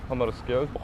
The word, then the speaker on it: Hammarskjöld: Dag Hammarskjöld.